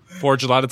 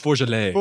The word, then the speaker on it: Forgelot: Forgelot, it's like, it's pronounced Forgelet.